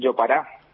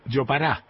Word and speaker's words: jopará: Y una cultura que mantuvo el blanco fue esta muy particular de que les quería contar, que es del yopará, una comida típica de acá, ¿viste? – Yopará. – Yopará se llama. Se escribe con J, jopará. – Ah, listo. – Y se lee yopará, digamos.